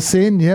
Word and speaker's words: Seine: Is that how you say it? – The Seine.